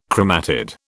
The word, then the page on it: chromatid: British English